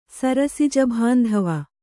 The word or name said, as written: ಸರಸಿಜಭಾಂಧವ